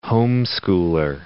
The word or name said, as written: homeschooler